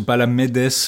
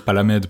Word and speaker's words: Palamedes: Normalement, on devrait dire probablement Palamides ou Palamedes, le, le S final se prononcerait probablement, mais on va dire Palamède parce que voilà.